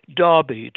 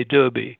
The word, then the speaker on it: Derby: Derby, to pronounce it in the English way. Here we would say Derby.